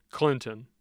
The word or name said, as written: clinton